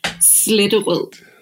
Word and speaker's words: Sletterød: And then you have Diget, Sletterød. So again, it's the sound you need to make, like you're throwing up or something. That's the Danish way to pronounce things.